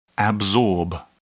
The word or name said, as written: absorb